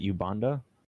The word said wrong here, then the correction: Umbanda: U-M-B-A-N-D-A, Umbanda.